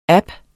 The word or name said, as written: app